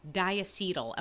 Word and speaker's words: diacetyl: So over a decade ago, workers in a microwave popcorn factory were sickened by breathing in diacetyl. Am I saying that correctly? Diacetyl? – Mm-hmm. – No. – Yep. – I am? Okay.